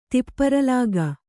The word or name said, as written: ತಿಪ್ಪರಲಾಗ